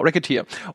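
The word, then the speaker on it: Recettear: Ich habe jetzt nämlich gespielt, das heißt Rezeteer, Racketeer, Racketeer wird ausgesprochen, wegen dem Wortspiel, genau, Racketeer.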